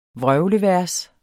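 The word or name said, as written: VRØVLEVERS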